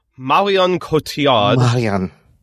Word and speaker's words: Cotillard: And finally, Christian Bale, and I had to look this up so I knew I pronounced it right, and Marion Cotillard from The Dark Knight Rises.